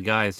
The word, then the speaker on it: Gui: The funny thing is, I think his real-life brother was one of the guys, too, named Guy, which is spelled G-U-I, like Guy.